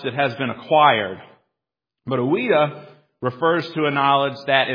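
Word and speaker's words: oida (οἶδα): So remember gnosko, and remember the word that John uses here has this root, a root that's pronounced oida.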